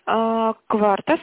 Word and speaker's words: quarta: Кварта, собака, кварта.су. – Так, кварта. Это как галочка, да, кварта? – Q-U-A-R-T-A пишется.